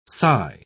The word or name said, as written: sai